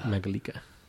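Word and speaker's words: Mangalica: Mangalika. How do you say it? Mangalika. – Mangalika. – It's like Mangalic. It's would like Metallica.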